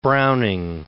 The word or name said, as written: Browning